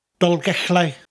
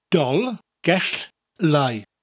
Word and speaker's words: Dolgellau: Dolgellau. Dolgellau. I'll slow it down. Dolgellau.